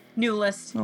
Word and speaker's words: Neulist: Neulist.